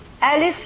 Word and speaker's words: Alif: You pronounce the Alif like the letter A in English. When you say Abel, okay, this is the A.